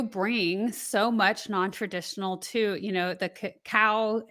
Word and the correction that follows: cacao: If I said that right, yeah, the cacao.